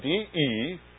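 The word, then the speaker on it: de (δέ): This is the letter de, D-E, de, is the way we pronounce it.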